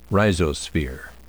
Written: rhizosphere

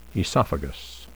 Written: esophagus

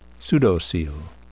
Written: pseudocoel

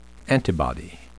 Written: antibody

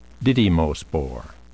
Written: didymospore

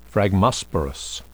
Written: phragmosporous

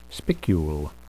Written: spicule

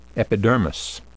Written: epidermis

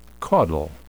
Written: caudal

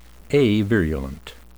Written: avirulent

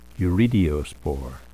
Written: urediospore

